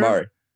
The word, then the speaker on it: Jamari: Yep, Jamari.